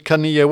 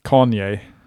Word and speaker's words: Kanye: Och så går de till Kanye West. Uttalade det rätt? – Nej, Kanye.